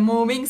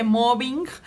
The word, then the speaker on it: mobbing: también es muy importante que no se dice moving, se dice mobbing.